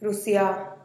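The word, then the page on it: rusiaa: Teotitlán del Valle Zapotec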